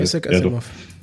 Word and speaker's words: Isaac Asimov: Wird ja wirklich so ausgesprochen? Ich weiß es gar nicht. Äh, ist ja, ja eigentlich. – Äh, Isaac Asimov. – Ja, genau.